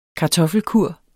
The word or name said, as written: kartoffelkur